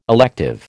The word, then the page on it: elective: American English